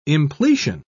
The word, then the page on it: impletion: British English